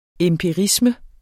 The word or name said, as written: empirisme